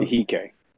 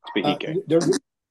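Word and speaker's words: Behike: I can't pronounce that right. I'm sure. – Bihike. It's Bihike.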